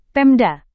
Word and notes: pemda: Indonesian

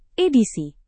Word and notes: edisi: indonesian

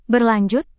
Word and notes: berlanjut: Indonesian

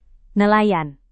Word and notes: nelayan: Indonesian

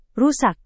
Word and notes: rusak: indonesian

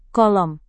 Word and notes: kolom: indonesian

